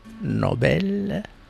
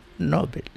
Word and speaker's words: Nobel: Por un lado, la pronunciación correcta es Nobel y no Nobel.